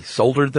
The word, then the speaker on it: soldered: soldered them shut. – 8,000 tins of food. – 8,000. – Mm-hmm. – He soldered them shut. – Apparently, that's how they say it in the UK.